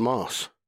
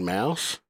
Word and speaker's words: Maus: He's like, no, I'm pretty sure, dude, it's pronounced mouse. I was like, it's about mice. That's the German word for mouse. There are German Mauser pistols that are spelled Mauser. They're not called Mauser pistols. They're called Mausers. And he kept arguing with me about it. And I was like, hey, we got a friend that lives in Germany. We're like, hey, Tim, how do you pronounce this word? And he's like, oh, that's mouse, like Mickey Mouse.